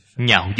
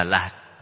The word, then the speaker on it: Nhạo: Nhạo dục, bất đồng. Nhạo là yêu thích. Ở chỗ này không đọc là lạc. Đây là đọc chạy âm. Nhạo là yêu thích, mong muốn.